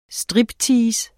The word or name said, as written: striptease